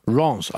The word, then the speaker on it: Rennes: How do you pronounce it? – Rennes. – Rennes.